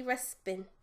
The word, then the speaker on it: Ruxpin: It's Teddy Ruxpin.